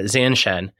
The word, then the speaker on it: Zanshin: I was really interested in this concept of, and I don't know if I'm going to say it right, Zanshin? – Yeah, Zanshin.